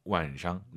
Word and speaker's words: 晚上: 그럼 할머니를 떠올리세요. 할머니. – 奶奶. – 발음 똑같죠? 성조가 晚上, 奶奶. 그죠?